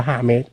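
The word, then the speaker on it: James: คือคำว่าเจมภาษาสเปนมันออกเสียงว่าฮาเมส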